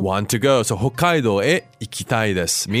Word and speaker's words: 北海道: Kako さん、この北海道の発音, the pronunciation of Hokkaido, could you do it a few times for us? It's a little bit different. Because in English, we just say Hokkaido. – Oh. – But in Japanese, 日本語の発音はちょっと違いますね。 – Okay. 北海道。北海道。 – So, we have the double consonant on that K, the Hokkaido.